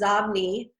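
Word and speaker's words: Xobni: If I said the name, which it's pronounced Zobni, if I said to all of your listeners, how do you spell Zobni?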